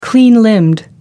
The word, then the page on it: clean limbed: American English